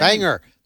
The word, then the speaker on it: Bangor: Bangor. They pronounce Bangor. It Bangor, Maine.